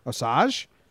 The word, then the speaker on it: Osage: There was a tribe of Native American people called the Osage. I don't know how to spell it. – Osage. – Osage? – Osage.